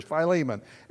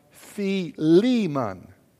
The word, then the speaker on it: Philemon: Now, that's how I have pronounced it for 40 years, Philemon. It's really pronounced Philemon. It's the Lee that gets the accent there. Philemon.